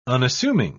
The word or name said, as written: unassuming